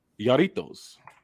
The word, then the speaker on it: Jarritos: My next one is the pineapple Yarritos, but it's spelled Jarritos.